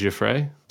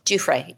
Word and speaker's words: Giuffre: Her name is pronounced Giuffre. – Giuffre, yes. – Giuffre.